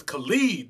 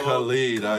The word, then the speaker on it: Khalid: Khaled. I don't know. – The nigga's name is Khalid, dog. – Khalid, I'm sorry, y'all.